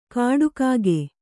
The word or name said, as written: ಕಾಡಕಾಗೆ